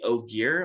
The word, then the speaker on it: Ogier: Was the Ogier.